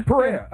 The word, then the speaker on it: Pereira: How do you say his last name? – Pereira.